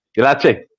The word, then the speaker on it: Lecce: Was it the lease? I think it was in Serie A. – Lecce.